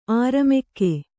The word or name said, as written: ಅಱಮಗ